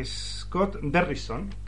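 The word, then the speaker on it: Scott Derrickson: El, director es el es, Scott Derrison,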